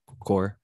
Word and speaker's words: coir: coir or core, if you want to pronounce it that way, if that matters.